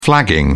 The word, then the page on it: flagging: British English